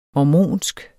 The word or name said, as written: mormonsk